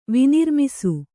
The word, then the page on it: ವಿನಿರ್ಮಿಸು: kannada